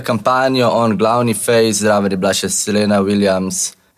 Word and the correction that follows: Serena: Serena, zakaj vse izgovorim?